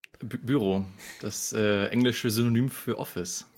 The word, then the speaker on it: Bureau: Naja, es ist am einfachsten für die Deutschen tatsächlich. Also meistens denkt man halt, dass es irgendwas Französisches ist. Und dann siehst du da irgendeinen Rainer, 45, der sagt, Collector Büro. Dann sagst du, nee, ich spreche einfach Büro aus, dann passt das.